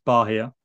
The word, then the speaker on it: Bahia: Um, we then played, we had two more games then before our Copa Sudamericana final against Bahia.